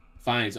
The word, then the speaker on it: Fiennes: How do you say it? – Fiennes. – Fines. Okay, Sandra Bullock.